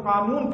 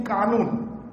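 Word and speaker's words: قانون: Sahib, but you can't spell the word qanun properly. Instead of saying qanun, you say kanun.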